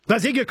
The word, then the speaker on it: Bazinga: Rhymes with finger, starts with B-A-Z, Baz. – Bazinga. – You can do it. You can do it. – Bazinga. – Bazinga, correct.